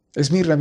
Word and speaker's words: Esmirna: En la palabra Esmirna puedes escuchar mir en ella. Esmirna viene de la palabra hebrea mir, se pronuncia Esmirna en griego, y significa amargo, amargura.